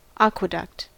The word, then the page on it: aqueduct: American English